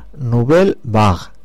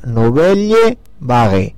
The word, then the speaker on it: Nouvelle Vague: Y que son Nouvelle Vague, ¿eh? En francés. Si lo digo pronunciando en castellano, diría Nouvelle Vague. ¿Eh? Y si lo digo deletreando, Nouvelle espacio Vague, ¿vale?